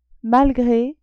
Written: malgre

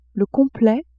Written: complet

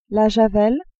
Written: javel

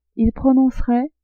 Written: ils prononceraient

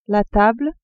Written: table

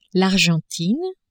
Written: Argentine

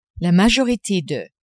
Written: la majorite de

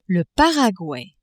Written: Paraguay

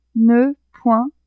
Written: ne-point

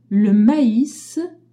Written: mais

